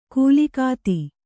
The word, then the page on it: ಕೂಲಿಕಾತಿ: Kannada; kūlikāti